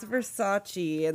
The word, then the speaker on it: Versace: It's Versace. And then she realizes that she's been saying it wrong.